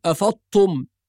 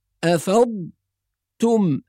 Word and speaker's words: أفضتم: إذن القراءة الصحيحة تكون بتحقيق الضاد الساكنة وبعدها تاء مضمومة، والتدريب على هذه الكلمة يكون أولا بتسكين الضاد، نقول أفض أفض، نسكن الضاد، ثم نقول تم، تاء مضمومة، أفض تم، هذا في التدريب حتى يستقيم النطق، فنربط الكلمة على بعضها ونقول أفضتم أفضتم، ولا نقول أفضتم كأنها بالطاء.